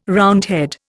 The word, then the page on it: Roundhead: Indian